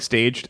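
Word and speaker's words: stage: which is spelled like staged and just means interned. So I always thought it was pronounced the other way.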